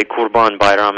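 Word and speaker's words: Kurban Bayram: We say Qurban Bayram in Turkish.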